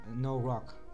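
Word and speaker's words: noroc: You have to pronounce it that. No rock. No rock. – No rock. – No rock. – No rock. – That's what it says. – No rock. – No rock. It's Moldovan. – Moldovan. – Pronounced no rock. It means luck.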